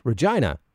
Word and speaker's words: Regina: For example, one of the slogans was Regina, the city that rhymes with fun.